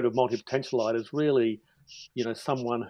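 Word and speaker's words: multipotentialite: Because you describe yourself as a multi-potentialite.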